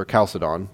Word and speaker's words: Chalcedon: And so I'm going to move to this point to the Fourth Ecumenical Council, which is the Council of Chalcedon, or Chalcedon, that H is silent.